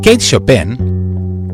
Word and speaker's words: Chopin: Kate Chopin, escrito Kate Chopin y pronunciado medio en inglés, medio en francés,